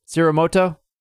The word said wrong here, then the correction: Sugimoto: What? Oh, Sugimoto. – Sugimoto.